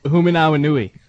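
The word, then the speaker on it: Hoomanawanui: How do you say his name? – Michael Humanawanui. – Not bad. – Not terrible.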